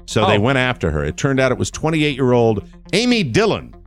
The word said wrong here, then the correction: Amy Dillion: I'm sorry, I pronounced that incorrectly. It's Amy Dillion. – Dillion. Of course, yeah.